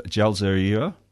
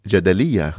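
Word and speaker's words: Jadaliyya: She's a co-founding editor of Jalzaria. How do you say that word? – Jadalia.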